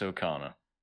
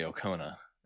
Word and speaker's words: Okona: I see that name and I have to stop myself from pronouncing it incorrectly. – I know. I always want to say Okona. – Right. Which is very annoying, but... – it's how the language works, really. When you look at that, you would think, yep, it's Okona. They could have spelled it O-K-A-N-A. – Right. Given that those final two syllables have the same vowel sound.